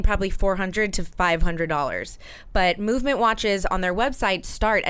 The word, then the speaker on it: MVMT: And so when MVMT, pronounced movement, but I just have to say it's MVMT.